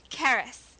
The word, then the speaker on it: Charis: Charis.